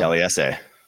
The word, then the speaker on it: Taliese: Taliese. – I thought the I wasn't pronounced. Okay, I'm learning. – Islander names, the trick, if there's a trick, or just like how they, is you pronounce every vowel.